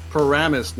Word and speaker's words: Paramus: Humdingers in Paramount, Paramus, New Jersey.